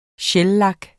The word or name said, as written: shellak